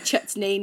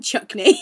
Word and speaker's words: chutney: Faye lovely, kindly laughed at me and said, you're saying it wrong. It's actually chutney, not chuckney.